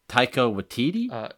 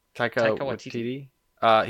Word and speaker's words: Taika Waititi: Taika Waititi? – Taika Waititi.